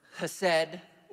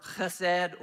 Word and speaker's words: chesed: It is, of course, the word chesed or chesed or chesed. People will pronounce it different ways.